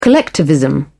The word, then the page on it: collectivism: British English